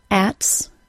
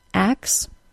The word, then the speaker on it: axe: The error that I often hear is non-native speakers dropping the K sound instead of the T sound in words like axe. Sorry, but that is not the correct pronunciation. If you say ats instead of axe, you could cause miscommunication to occur, so don't do that.